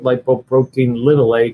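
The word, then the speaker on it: lipoprotein(a): For about $20 in a blood test, you find out your level of lipoprotein A. Some people pronounce it lipoprotein little a because it's lowercase.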